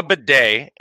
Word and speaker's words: bidet: It's called a bidet, and it's only pronounced that way because it's French in origin.